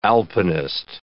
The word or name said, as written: alpinist